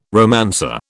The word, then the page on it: romancer: British English